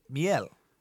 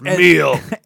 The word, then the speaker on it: miel: So I'm describing this coffee to somebody at work, and this Italian, somebody that spoke Italian, said, do you mean miel? – Oh, okay. – I said, oh, M-I-E-L. Yeah, that makes sense. That makes sense. – You're like, I'm American. It's pronounced meal.